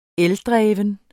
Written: ELDREVNE

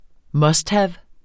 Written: musthave

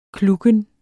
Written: klukken

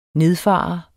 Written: nedfare

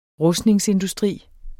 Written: rustningsindustri

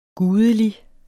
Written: gudelig

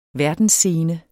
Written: verdensscene

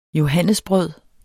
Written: johannesbrød

